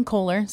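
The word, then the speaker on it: Kohlar: So it's K-A-I-T-L-Y-N and then it's Kohler, K-O-H-L-A-R. I like to say like the faucet company.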